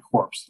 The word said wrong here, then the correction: corps: At least I hope. Corpse. C-O-R-P-S. – It's core. It's pronounced core.